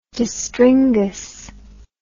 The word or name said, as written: distringas